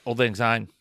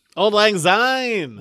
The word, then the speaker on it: Auld Lang Syne: Yeah, Auld Lang Syne. – Auld Lang Syne.